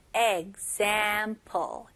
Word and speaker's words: example: 네, 여기서는 발음을 example. Example. G처럼 시작해서 그 다음에 Z로 넘어가는 거예요. Example. Example. Okay. 강세가 어디에 들어갈까요? 다시 한번 들어보실래요? Example. 네, 맞아요. Z에 들어가죠? Example. Example. 네, 그래서 발음도 중요하지만 강세도 똑같이 중요합니다.